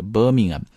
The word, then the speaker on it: Birmingham: 那么注意一下这个 Birmingham 这个单词的读音，不要把它读成 Birmingham 啊，这个 h sound 是被哑掉的，所以读的是 Birmingham。那跟它类似的，比如说像白金汉宫啊，你不要读 Buckingham，而是 Buckingham。啊，还有非常有名的球星 David Beckham 啊，你不要读 David Beckham 啊，这是 David Beckham Birmingham Buckingham 啊，直接那个地方就出来就好了啊。